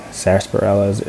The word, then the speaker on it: sarsaparilla: Like for me, I was just taking sarsaparilla. I feel like I'm pronouncing it wrong. Sarsaparilla. – Sarsaparilla. I don't. I hear it's a weird one. It has just like a random R in the middle.